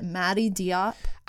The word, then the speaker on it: Mati Diop: There's is it Mati Diop? I that's how I, that's how I've heard it pronounced.